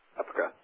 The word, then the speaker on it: EPCRA: how do you pronounce that acronym? Is it EPCRA? – EPCRA. Yeah.